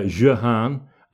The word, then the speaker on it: Jeanne: And and just one point about Joan of Arc, of course, to the French, she's she's Jeanne d'Arc, Jeanne d'Arc, or and, and, her name may have been pronounced more like uh, Jehan, and the Arc really came on after, although her father was Jacques d'Arc.